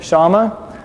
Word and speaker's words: Shammah: Shammah ends with a hey, and you pronounce it Shammah.